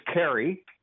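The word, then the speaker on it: Carey: My name is Carrie.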